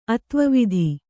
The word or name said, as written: ಅತ್ವವಿಧಿ